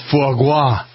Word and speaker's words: foie gras: So, let's have a little more foie gras. Is that how you pronounce it? – A little more foie gras, right.